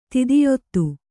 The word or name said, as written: ದೈತ್ಯತೆ